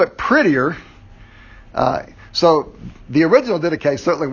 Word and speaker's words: Didache: It's pronounced Didache.